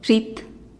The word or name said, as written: zhit